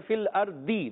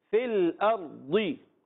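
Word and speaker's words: فی الأرض: دال جیسا پڑھے فل ارضی، دال سے ہی پڑھتے ہیں، نہیں یہ بھی نہیں۔ اچھا، بعض دال جیسے پڑھتے ہیں فل ارضی، یہ بھی نہیں کرنا۔ آپ نے اس کا تلفظ سیکھنا ہے، جب آپ زبان کا بغلی کنارہ، زبان کی کروٹ اوپر کی داڑھوں سے، جڑوں سے لگائیں گے تو ادا آواز آئے گی فل ارضی۔ کیا آواز آئے گی؟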